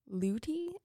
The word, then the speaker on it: Leuty: That same year, something notable, is the 179-foot steamer Leuty, I think is how it's pronounced. L-E-U-T-Y.